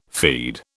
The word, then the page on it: feed: British English